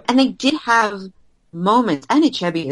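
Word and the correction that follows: Anichebe: is just, am I not saying it correctly? I also need somebody to tell me how to pronounce his name correctly. – You're almost there. – Anichebi. Nope, that's not it. – That's it. – Is it? – Yes.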